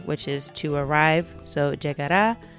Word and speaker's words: llegará: So, for example, it starts out pronto llegará. So that llegar uh, is followed by uh, the a sound at the end, and that's where the emphasis is.